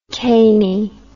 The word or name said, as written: cany